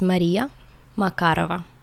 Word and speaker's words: Maria Makarova: Mine is Maria Makarova.